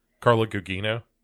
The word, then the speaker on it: Carla Gugino: Carla Gugino.